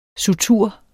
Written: SUTURER

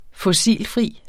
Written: fossilfri

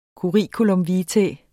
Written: curriculum

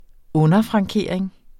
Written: underfrankering

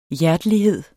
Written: hjertelighed

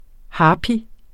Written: harpy

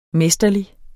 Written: Mesterlig

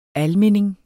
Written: ALMINDINGERS

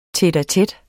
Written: tete-a-tete

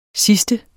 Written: sidste

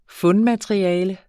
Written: fundmateriale

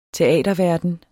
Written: teaterverden

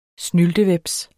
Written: snyltehveps

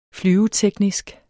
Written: flyveteknisk

